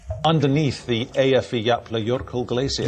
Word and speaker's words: Eyjafjallajökull: No, il nome è Eyjafjallajökull.